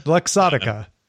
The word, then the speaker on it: Luxottica: Did I say it wrong? Luxottica? – Luxottica.